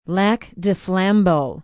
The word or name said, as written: lac du flambeau